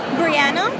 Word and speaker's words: Breana: Breana. – Breana, how do you spell that? – B-R-E-A-N-A.